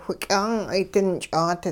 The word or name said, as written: whiky'aang'ay dinch'aat